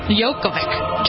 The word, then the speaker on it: Djokovic: Is that how you say his name? – Yeah. Djokovic. – Djokovic. I don't know. It's a silent D, I think.